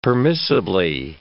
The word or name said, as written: permissibly